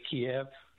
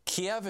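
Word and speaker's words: Kyiv: please, don't say Kiev, say Kiev. – Kiev is the Russian pronunciation. Kiev is the Ukrainian pronunciation.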